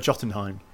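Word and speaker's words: Jötunheim: Is it Jotunheim or Jotunheim? – Jotunheim.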